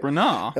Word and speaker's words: Branagh: Branagh? – And then, Kenneth Branagh, darling. That's how you pronounce it in the world of theatre.